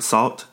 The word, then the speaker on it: Sault: No one is really sure how many members there are in Salt, and it's spelled S-A-U-L-T.